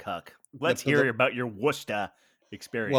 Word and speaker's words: Worcester: It's spelled Worcester. Like Worcestershire sauce on this steak. – Yes. – Yeah. – But they say Worcestershire sauce, so it's the same pronunciation.